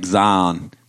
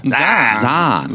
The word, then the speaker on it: Zion: Yeah, like, the best part of that is like Zion. Like, you just make it one syllable. – Zion.